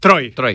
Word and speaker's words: Troy: ¿Troy? – Troy. Como Troy McClure.